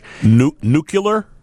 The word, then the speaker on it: nuclear: Many, many people mispronounce this. Former President Bush used to mispronounce it all the time. The second President Bush. – Nuclear? – Yes.